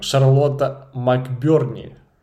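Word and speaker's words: Charlotte McBurney: A Plague Tale Requiem, Шарлотта МакБёрні. Правильно я вимовив? – Так, так.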